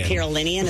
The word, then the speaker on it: Carolinian: Scotty McCreary, rest in peace, Charlie Daniels, my fellow North Carolinian, I guess, is that what it is? – Carolinian, is that how you say it? Okay. – That's right. Fine.